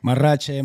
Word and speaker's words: Marrache: Marrache, mariachi.